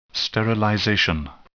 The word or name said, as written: sterilization